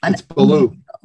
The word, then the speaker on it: Billou: It's Billou.